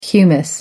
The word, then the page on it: humus: American English